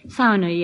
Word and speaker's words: sauna: Sauna, yes. The correct pronunciation is sauna.